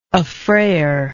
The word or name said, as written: affrayer